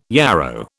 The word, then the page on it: yarrow: British English